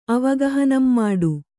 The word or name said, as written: ಅವಗಹನಂಮಾಡು